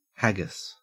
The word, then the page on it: haggis: Australian English